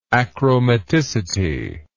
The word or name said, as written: achromaticity